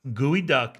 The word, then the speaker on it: geoduck: I'm telling you, look up gooey duck, G E O D U C K gooey duck. – It's, it's in the clam geoduck, family. But yeah, it does say it's pronounced gooey duck.